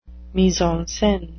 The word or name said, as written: mise en scene